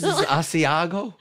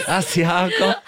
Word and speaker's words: Asiago: But look, what is Asiago? – That is, okay. – Beautiful pronunciation. – Thank you. – It's gorgeous. – Asiago.